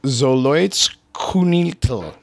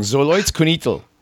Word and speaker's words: Xoloitzcuintles: Xoloitzcuintles. Excuse me, I said it wrong. Xoloitzcuintles. There you go.